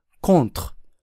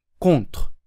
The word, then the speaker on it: contre: 手の発音はもともと、 t、 t という舌打ちの音だけですから、 contre は母音を入れずに、 contre、 contre と発音します。